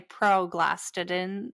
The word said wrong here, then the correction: prostaglandins: Prostaglandins. – Prostaglandins.